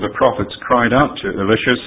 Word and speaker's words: Elisha: There were two great prophets at this time of Israel, Elijah, and therefore some will say Elisha to distinguish from Elijah, some say Elisha. Perhaps more close to the original name is Elisha. So that's the way I'll pronounce it as we read here this morning.